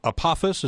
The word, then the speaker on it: Apophis: And one of the kind that we're talking about here, this one that you're talking about called Apophis, is that how it's pronounced? – Yes, exactly.